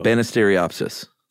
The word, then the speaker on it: Banisteriopsis: Yeah, but if it's it's pronounced like it looks. – Yeah, though. Banisteriopsis. – You got it.